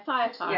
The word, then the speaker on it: Thyatira: Thyatira? – That's fine. – Thyatira? – Yeah, Thyatira.